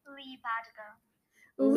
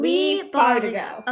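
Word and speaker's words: Leigh Bardugo: Leigh Bardigo. – Leigh Bardigo. Okay. I was right about the Bardigo part. – Yeah, I just was Leah, but just Leah.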